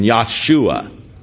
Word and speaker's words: Yahshua: The Jah, or the Joe, Yah, actually in Hebrew, the J has a Y pronunciation, Yahshua.